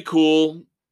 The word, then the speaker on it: Kuhl: Tommy Kuhl, K-U-H-L. I anticipate, I suspect that name is pronounced, though, Kuhl.